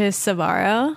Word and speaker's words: Sbarro: Is that how you say it, Sbarro? – Yeah, I think so.